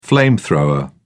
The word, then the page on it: flamethrower: British English